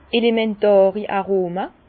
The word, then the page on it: elementàri Aroma: Bas-Rhin; Herrlisheim